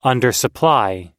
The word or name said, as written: undersupply